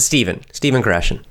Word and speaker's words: Stephen Krashen: We pronounce it Stephen Krashen. But his name is spelled S-T-E-P-H-E-N. Stephen Krashen, K-R-A-S-H-E-N, if you're listening.